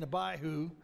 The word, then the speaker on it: Abihu: I had that guy look it up on the internet and pronounce it, so I got it correct. Nadab and Abihu, they offered a strange sacrifice.